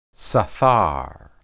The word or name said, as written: sathar